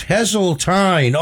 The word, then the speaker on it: Hazeltine: It's Hazeltine. Oh, a Hazeltine rhymes with pine.